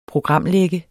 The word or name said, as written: programlægger